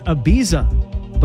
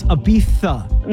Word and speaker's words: Ibiza: Like, for example, I thought it was Ibiza, but apparently it's Ibiza. – My children tell me I've been pronouncing it wrong.